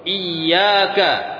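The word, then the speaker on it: iyyaka (إياك): Ya, ini ketika antum mendapati iya, ya, iya dengan ya yang ditasydid. Kalau antum tidak mentasydid huruf ya-nya, itu maknanya berubah. Iyyaka dengan iyyaka maknanya berbeda. Kalau iyyaka na'budu artinya hanya kepadamu kami menyembah. Kalau antum bacanya iya tanpa ditasydid, iyyaka na'budu artinya hati-hati kalian dari beribadah.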